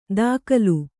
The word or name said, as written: ದಾಕಲು